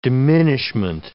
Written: diminishment

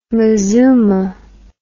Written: mazooma

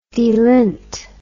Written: delint

